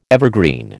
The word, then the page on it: evergreen: American English